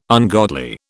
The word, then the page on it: ungodly: British English